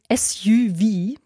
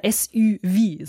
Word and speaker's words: SUV: Ich finde es ja immer schön, dass englische Abkürzungen in Frankreich konsequent französisch ausgesprochen werden. SUV oder SUV, so ist es richtig.